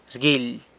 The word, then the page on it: rguily: sanlucasquiavini